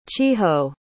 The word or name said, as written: chiho